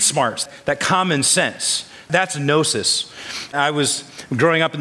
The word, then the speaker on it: gnosis: One word is gnosis, say it with me, gnosis, and epinosis, not gnosis, but gnosis. The G is silent in the Greek.